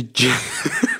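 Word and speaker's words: Djesse: The Jesse. Silent D.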